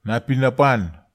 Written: napinapan

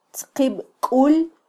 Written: tzqibkul

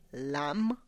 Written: lamm

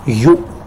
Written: yu

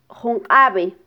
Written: junqabe

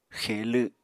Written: xe7lw7

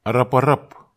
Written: araparap

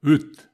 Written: wyt

